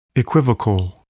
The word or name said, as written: equivocal